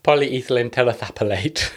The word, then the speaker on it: polyethylene terephthalate: Can't pronounce it. Polyethylene. – Polyethylene terephthalate.